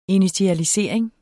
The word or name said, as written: initialisering